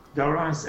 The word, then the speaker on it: Dorrance: Michael Olivier, have I pronounced Dorrance right? – Dorrance, absolutely. Dorrance is the old French name for the man that is now Durand, D-U-R-A-N-D.